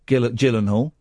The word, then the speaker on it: Gyllenhaal: And so Jake Gyllenhaal is a copper. Do you know why he's a good actor, Pablo? Because he had two quirks as a copper, Gyllenhaal. And it's a soft G, like Jif.